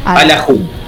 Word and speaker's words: alajú: Alajú, con acento en la U.